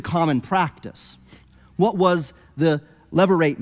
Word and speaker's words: levirate: Later in the book of Deuteronomy, Moses, by God, will regulate, give laws regulating a levirate marriage, that's how you say it, but not commanding it.